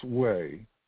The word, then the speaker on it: hui: So I guess it's Wei, spelled H-U-I, pronounced Wei.